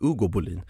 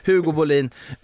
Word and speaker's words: Hugo Bolin: Jag insåg att jag uttalade liksom spanskt Hugo Bolin. Hugo Bolin till Borussia Mönchengladbach på ett lån med köpoption.